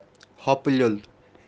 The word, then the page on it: hopu-leol: kashmiri